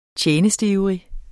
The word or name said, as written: TJENSTIVRIGT